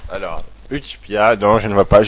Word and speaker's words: Utchpia: Ça se prononce Utspia. C'est de la langue d'Oïle. – Ah, pouvez-vous nous appeler le mot? – Alors, U-T-C-H-P-I-A.